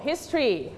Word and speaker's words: history: only two syllable history one more time history